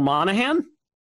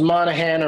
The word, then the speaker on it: Monaghan: I've never known for sure if it's Monaghan or Monaghan, if you pronounce the G. – Uh-huh. – But I think you're probably right about not pronouncing the G, and you're definitely right about everything else.